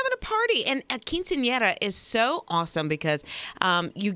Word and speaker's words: Quinceañera: I actually called my daughter last night and I was like, let's make sure I can say it. Quinceañera.